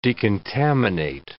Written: decontaminate

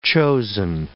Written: chosen

